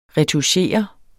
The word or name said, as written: RETOUCHEREDE